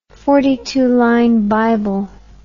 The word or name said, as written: forty-two